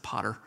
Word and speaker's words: pater: It's the pater is just the generic P-A-T-E-R. It's sometimes pronounced pater, but I'm a redneck from South Georgia, so I've always pronounced it potter. Sounds like the potter in the clay, but it's not. It's P-A-T-E-R.